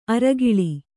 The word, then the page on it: ಅರೆಕಾಲ್: arekāl